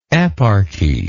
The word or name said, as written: eparchial